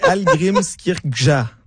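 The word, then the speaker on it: Hallgrímskirkja: Algrimskirkja. Êtes-vous meilleur que moi pour le prononcer? – Je vais m'essayer. Algrimskirkja. – Bravo. – C'est à peu près ça.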